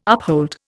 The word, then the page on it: uphold: Indian